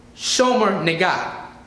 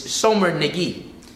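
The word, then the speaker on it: Shomer Negiah: There is a concept called Shomer Nagai. Or I'm saying it wrong. It's Shomer Nagi. And I'm probably still saying it wrong because I'm not Jewish, but I tried my best.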